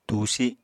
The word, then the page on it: tusi: Apatani